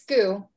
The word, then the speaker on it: Goux: It's Goux, but you're close.